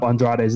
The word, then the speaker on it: Andrade: It's like, why did somebody not get in his ear and just say Andrade?